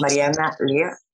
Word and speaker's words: Mariana Lea: Her name is Mariana Lea.